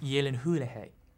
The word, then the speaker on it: Gyllenhaal: It's pronounced Gyllenhaal.